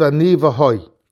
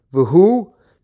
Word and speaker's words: Ani v'hoi: says that the proper way of pronouncing it is not Ani v'hu, but it says that it's Ani v'hoi. And he quotes that the Yavetz says that when he was a young boy still, he heard his father pronounce it, and the way his father pronounced it is Ani v'hoi Hashina.